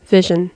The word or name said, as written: vision